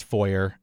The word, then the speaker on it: foyer: Most everybody else says foyer, and that's fine with me. I'm going to assume that that's the more accepted English pronunciation anyway, is foyer. But I'm okay with either one. – Well, apparently, there's some debate. Apparently, Canadian and British English prefers your method. – Mm-hmm. – American English prefers foyer. – Mm-hmm. – But there's like a lot of spillover. I'm not bothered by either pronunciation.